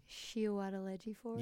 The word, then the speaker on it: Chiwetel Ejiofor: Chiwetel Ejiofor? – Yes, first go, straight at the bat.